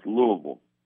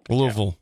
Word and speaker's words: Louisville: It's Louisville. – What did I say? – Louisville.